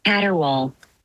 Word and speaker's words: caterwaul: I don't even know how to say this. – Caterwaul. – Caterwaul. – Caterwaul. Okay. – Caterwaul. It's a verb, and it's spelled C-A-T-E-R-W-A-U-L. It's caterwaul. What it means.